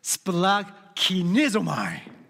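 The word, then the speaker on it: splakinizomai (σπλαγχνίζομαι): It is pronounced splakinizomai.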